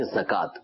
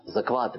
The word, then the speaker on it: زکات: یعنی یہ جو واو کے اوپر کھڑا زبر لگا ہوتا ہے، اس کو کھڑا زبر کاف کے اوپر پڑیں گے، زکات، زکوات نہیں. تو یہ تلفظ ٹھیک کر لیجئے.